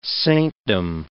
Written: saintdom

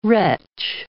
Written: wretch

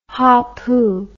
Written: hapu

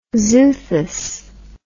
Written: xuthus

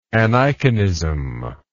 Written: aniconism

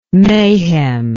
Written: maihem

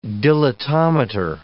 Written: dilatometer

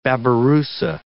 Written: babirusa

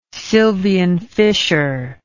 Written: sylvian fissure

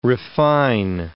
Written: refine

